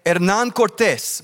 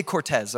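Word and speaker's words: Cortés: Hernan Cortes. But I'm not just going to keep saying like that because then you're going to think I'm pretentious for continually pronouncing it right. I'll just say Cortez, okay? And we'll go like that from this point forward, all right?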